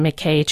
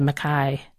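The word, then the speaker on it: MacKaye: Yeah, just like me calling the BMT the Benton McKay Trail instead of Mackay. But I got corrected on that one.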